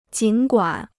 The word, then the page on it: 尽管: jǐn guǎn